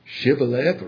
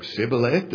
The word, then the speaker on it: Shibboleth: Shibboleth or Sibboleth, does it really make that much difference? Yeah. It cost 42,000 people their life in mispronouncing this word.